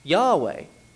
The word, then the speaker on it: Yahweh: Well, God's name is pronounced Yahweh. At least we think it is pronounced Yahweh.